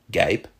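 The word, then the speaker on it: guêpe: Today, those words are pronounced gaip and gair.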